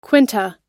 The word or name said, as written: quinta